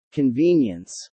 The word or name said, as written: convenience